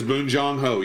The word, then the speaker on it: Bong Joon-ho: Is that Bong Joon-ho? I'm saying his name wrong. – That is Bong Joon-ho, yes.